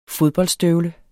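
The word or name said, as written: FODBOLDSTØVLER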